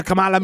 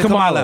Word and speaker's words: Kamala: Your mother named me Kamala, me okay, it's Kamala.